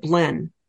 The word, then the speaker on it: Belen: So I always ask people, so how do you pronounce the name of your town? And a lot of them would pronounce it Blen. So, and I put a little bit more of an E-N on it so that listeners can see that's B-E-L-E-N, Belen, Belen, a big railroad town.